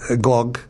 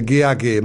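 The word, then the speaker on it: Gág: Escreve-se com W e Wanda, ou Wanda, e com GAG, mas lê-se Gog, porque o próprio livro explica nas badanas que o nome dela rima, diz assim: atenção à pronúncia, o apelido rima com blog e não com blag. Portanto, não é Wanda ou Wanda Gag, mas sim Wanda Gog.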